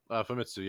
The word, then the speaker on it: Famitsu: Am I saying that right? – Famitsu, yeah.